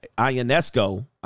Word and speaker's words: Ionescu: Ionescu, I believe is how you pronounce it.